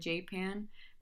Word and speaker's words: Japan: In my research, I found that locals apparently called it J-Pan, but it's spelled like the country.